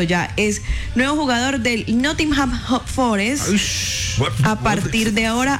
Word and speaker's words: Nottingham: Este equipo, no sé cómo se dice, jugador de Nottingham.